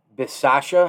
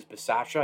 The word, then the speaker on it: Bisaccia: He's a special teams coach, and he thinks that, you know, he's going to do really, really well because Rich Bisaccia, I think is how you pronounce that last name. It's two C's, Bisaccia. I think it's a soft double C.